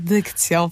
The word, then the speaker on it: dykcją: O, powiedziałam jeszcze tak, nad dykcją. Nie zaakcentowałam tego dobrze. Super w ogóle, to jest mega śmieszne, że tak powiedziałam. Nad dykcją, popracuj.